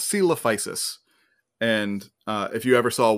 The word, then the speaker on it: Coelophysis: Coelophysis.